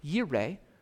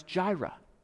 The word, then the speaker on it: yireh: It's that Hebrew word, yireh, what we more commonly pronounce Jireh.